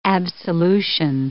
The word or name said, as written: absolution